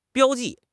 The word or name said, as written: 标记